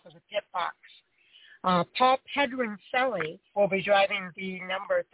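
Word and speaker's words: Pedroncelli: And then we got one of the Pedronicillis, PJ Pedronicilli from Sonoma, California, in the cell phone number 33 Chevrolet. – Yeah, you're adding an extra syllable there. It's Pedronicilli.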